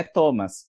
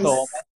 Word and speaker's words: Thomas: O meu nome é Thomas. Só que eu faço parte daquele grupo de nomes que tem várias formas de se escrever. O meu é Thomas.